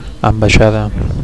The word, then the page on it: ambaixada: Catalan